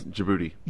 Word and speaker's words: Djibouti: How do you pronounce the next one? Djibouti? – It's Djibouti.